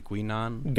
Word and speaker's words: Guinan: So I think Whoopi Goldberg's character, which I can never say her name properly, Guinan.